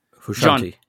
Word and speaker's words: Frusciante: And if you listen to Hendrix and you listen to I can't even pronounce his name. – Frusciante.